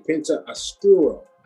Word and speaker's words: Penta Oscuro: It was the qualifier match for the All Atlantic Championship match that's taking place at Forbidden Door between Malakai Black and Penta Oscuro. Did I say that right, KG? – Correct. Correct.